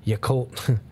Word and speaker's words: Yacolt: So a councilwoman, Michelle Dawson of Yellicott, I'm probably saying it wrong. Yacolt, Yacolt, Washington,